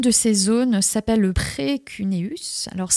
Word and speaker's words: précuneus: Comment ça s'écrit euh, le terme Alors P-R-E-C-U-N-E-U-S, e e précuneus.